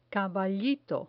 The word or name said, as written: caballito